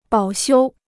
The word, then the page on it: 保修: bǎo xiū